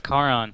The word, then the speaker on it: Charon: Out of like Charon. That's the right one, because it's a hard C.